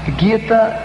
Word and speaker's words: Gita: Gita é a pronúncia porque vem de um livro indiano chamado Bhagavad Gita.